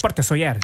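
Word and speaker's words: Port de Sóller: Porte de Soler. Ich glaube, es spricht man anders aus. – Porte Soler.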